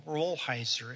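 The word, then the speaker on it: Rolheiser: And I told him, Rollheiser. And finally said, look, if you get mixed up, it's like the beer. Just say Budweiser. It's close enough.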